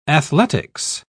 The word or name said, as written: athletics